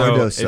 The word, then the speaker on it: Cardoso: So Cardoso.